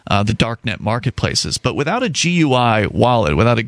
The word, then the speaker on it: GUI: Right, GUI, and that's how I generally hear GUI pronounced.